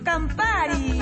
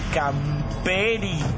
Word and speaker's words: Campari: Sí, traenos Campari. Campari. – No sabe pronunciar Campari. Campari. – Sí, ¿qué le sirvo? – ¿Me das un camperi? – ¿Un qué? – Un campana. – ¿Perdón? – Campari.